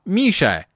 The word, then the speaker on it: Миша: Имя его произносили мягко с «я» на конце. «Миша!» — как он себя называл.